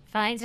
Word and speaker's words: Fiennes: Fiennes, did I say that right? – Andrew? Oh, yes.